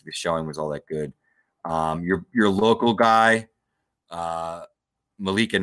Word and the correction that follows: Malachi: Knight. Did I say that right? – Malachi Knight.